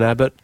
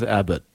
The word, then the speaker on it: Abbott: Dan Abbott, nothing rhymes with Abbott. Rabbit.